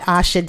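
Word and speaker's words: Aasha: I'm on IG at Asha Davis. It's A-A-S-H-A. Maybe that's why I like my name is spelled weird. Anyway, at A-A-S-H-A-D-A-V-I-S.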